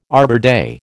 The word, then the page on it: Arbor Day: American English